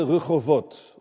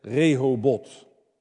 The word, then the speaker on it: Rehovot: En dan de derde Rehovot, of zoals wij zouden zeggen, Rehobot. Maar dit is de Hebreeuwse uitspraak, Rehovot.